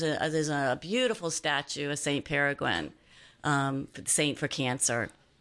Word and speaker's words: Peregrine: Peregrine. – Yeah. – Peregrine. – Peregrine. That's it. Peregrine.